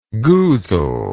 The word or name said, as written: goozle